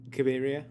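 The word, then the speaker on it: Cabiria: and then follow that up with Knights of I'm never going to pronounce it right. – Kiberia. – Yes, what you said.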